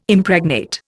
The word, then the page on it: Impregnate: Indian